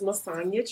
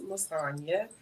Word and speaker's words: Masonia: So my name is Masonia Traylor, pronounced Masonia, and I am living in Atlanta, Georgia.